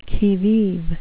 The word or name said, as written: qui vive